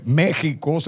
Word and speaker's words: México: Por eso es que, estimados, eh, México se pronuncia con J, pero esa X que ven ahí es una X ahogada que es propia de aquella forma de hablar.